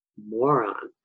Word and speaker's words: Moran: You had asked me to research our family name, Moran. And it's pronounced moron.